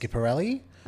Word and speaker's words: Schiaparelli: Yep, so it's pronounced Schiaparelli. Okay. Some people will say Schiaparelli, mm-hmm. but when you see an S, a C and an H, it's pronounced Sch.